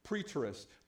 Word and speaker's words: preterist: Everybody say preterist. Yeah, I don't know if I'm saying it right either. But it's preterist.